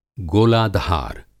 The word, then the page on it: गोलाधार: Hindi